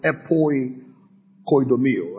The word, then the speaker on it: epoikodomeo: We can also, of course, the word to lay foundation here is the word epoikodomeo. Spell it for you. E-P-O-I-K-O-D-O-M-E-O. E-P-O-I-K-O-D-O-M-E-O.